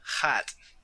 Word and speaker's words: Hut: So the way that they wrote it out in Russian, it's pronounced hat, like hat.